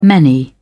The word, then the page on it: many: British English